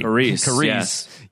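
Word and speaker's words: Carice: Must suck for the actress, Carys von Houten. I'm not sure if I'm pronouncing that right. – Carys.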